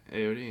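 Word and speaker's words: Ayoade: But Ralph Fiennes, Richard AOD, is that how you say his name? – Yeah, AOD, yeah.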